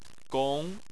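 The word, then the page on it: gong: tone 2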